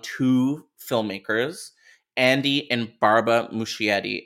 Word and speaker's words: Muschietti: andy muschietti yeah uh-huh.